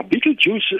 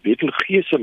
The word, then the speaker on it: Betelgeuse: Ja, Beetlejuice uh, eh, is eintlik maar die regte uitspraak daarvoor. Party mense sê dit Betelgeuse, maar dit is uh.